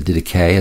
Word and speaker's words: Didache: There are extra-biblical writings like the Didache, or the Didache as it's variously pronounced,